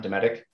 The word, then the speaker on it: Dometic: I pronounced it correctly, right, John? Dometic? – Yes.